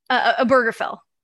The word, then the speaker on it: Obergefell: Obergefell.